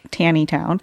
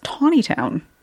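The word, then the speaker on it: Taneytown: And on June 30th, we find him at, now it's spelled T-A-N-E-Y. So you would think Tanny Town, but it's actually pronounced Tawny Town, as I learned from Tom Huntington's book about Meade.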